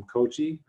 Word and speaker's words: Kochi: Like, for example, in Western India, we've had visitors from Kochi, I think I'm saying that right.